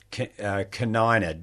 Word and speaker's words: canina: And I think you called them Carina? – Canina, Rosa Canina. – Rosa Canina, yes. – Yeah. Yeah. – How do you spell that? – C-A-N.